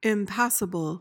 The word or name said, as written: impassible